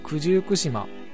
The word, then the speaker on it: 九十九島: 99 の島と書いてこれは九十九島と読むんですがそういう島々があります今回はそんな九十九島のお話をしていこうかなと思います。九十九島